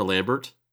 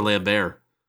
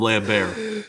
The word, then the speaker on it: Lambert: I learned that his name isn't Christopher Lambert. It's Christopher Lambert. – Lambert? – The T is silent.